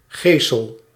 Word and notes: беда́: /bʲɪˈda/; /bʲɛ.ˈda/

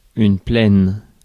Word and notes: plane: /pleɪn/

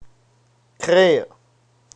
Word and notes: think: American English; /θɪŋk/

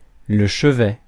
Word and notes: chevet: France; IPA: [lə ʃə.vɛ]

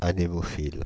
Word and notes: anémophile: France (Île-de-France); /a.ne.mɔ.fil/

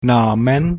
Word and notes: Namen: /ˈnaː.mən/

